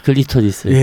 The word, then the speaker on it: 클리토리스: 그 혀로 크리스토스 해주는 게 제일 좋았다. 네네. – 클리토리스를. 예, 클리토리스. 아, 예.